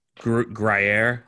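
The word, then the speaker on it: Gruyère: Gruyere cheese. – Gruyere.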